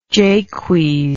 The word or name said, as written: Jaques